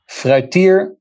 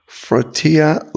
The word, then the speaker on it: Fruytier: So how does one pronounce the name of your law firm? – Fruytier Lawyers in Business. – Fruytier Lawyers in Business. Okay. – So only the first word is difficult.